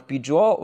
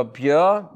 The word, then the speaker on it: Byor: Ähm, dann noch Bijor oder Björ, je nachdem, wie man ihn aussprechen will. B-Y-O-R, äh, hat auch auf dem Tomorrowland gespielt.